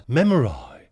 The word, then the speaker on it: memory: And this, incidentally, is one of these instances that I promised I would highlight for you, at least occasionally, where, strictly speaking, we would have to pronounce memory as memori, to rhyme with sky.